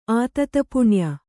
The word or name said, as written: ಆತತಪುಣ್ಯ